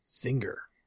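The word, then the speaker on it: Zynga: Zinger. What is that? – My yeah, that's a little too much emphasis on the G, I think.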